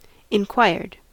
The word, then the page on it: inquired: American English